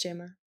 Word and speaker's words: JAMA: It's JAMA.